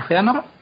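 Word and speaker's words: Fëanor: A nosotros, se quedan muy bonitas, una palabra con sus dos puntitos encima de la E, está muy bien, como Feanor, pero no significan absolutamente nada en la pronunciación.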